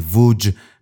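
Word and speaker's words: Łódź: همیشه با دوستانی کار می کند که در مدرسه سینمایی لودز و به تلفظ لهستانی ووج پیدا کرده.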